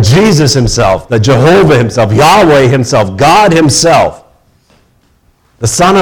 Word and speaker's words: Yahweh: Used to be more pronounced as Jehovah, but usually today it would be pronounced as Yahweh.